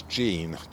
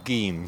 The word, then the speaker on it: gean: In fact, it's the wild cherry, and it's more commonly known as Jean, G-E-A-N as well, or Geen, some people pronounce it as.